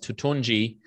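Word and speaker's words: Toutounji: That is how you spell the name, and it's pronounced Turturnji. And it's a Syrian name.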